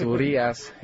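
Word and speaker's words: Urías: He's got the accent on the I, so it's Urias, you know.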